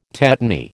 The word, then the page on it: tetany: American English